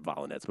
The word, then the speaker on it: Volynets: I know she pronounces it Volynets, but we like to call her Volynets.